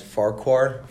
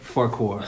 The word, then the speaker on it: Farquhar: I think it's pronounced Farquhar. – Farquhar. – Farquhar.